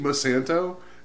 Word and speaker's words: Monsanto: Mama Santo? You mean Monsanto? Is that how do you say that? – Science.